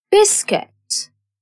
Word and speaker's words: biscuit: U is a silent letter in guitar. Biscuit. W is a silent letter in wrong.